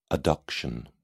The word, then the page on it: adduction: British English